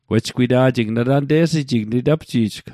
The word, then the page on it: witapji'jl: Mi'gmaq